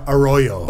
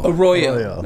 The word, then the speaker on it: Arroyo: You're fighting, let me make sure I'm pronouncing the name right, Arroyo, I can't, why am I having such a hard time with that? – Arroyo. – Arroyo. That's so easy. There's like Oreo, but different.